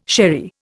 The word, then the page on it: sherry: Indian